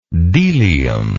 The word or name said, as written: delium